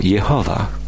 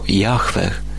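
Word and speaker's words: Jahwe: My wymawiamy je dzisiaj jako Jehowa, ale samogłoski zostały tu dobrane z hebrajskiego słowa Adonai, co znaczy Pan, którym Żydzi nazywali Boga, by uniknąć wypowiadania Jego świętego imienia. Wielu współczesnych uczonych uważa, że to święte imię powinno być wymawiane jako Jahweh.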